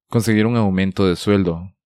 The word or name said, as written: Conseguir un aumento de sueldo